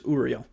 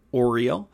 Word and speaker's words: Uriel: Uh, the last archangel that is listed in the Lesser Banishing Ritual of the Pentagram is Uriel. Um, sometimes it's spelled A U R I E L and it's pronounced Uriel. Uh, a lot of times people will pronounce this Oriel or Ariel.